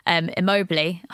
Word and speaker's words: Immobile: By the way, that Serie A game against Lazio where it ended 3-3, Immobile, hopefully I've pronounced that right. – Yes.